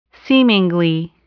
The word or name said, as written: seemingly